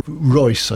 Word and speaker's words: Reuss: And one of the key players there was a man called Theodore Royce, I suppose it's pronounced, R-E-U-S-S.